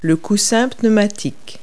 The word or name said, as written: airbag